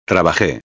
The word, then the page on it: trabaje: espanhol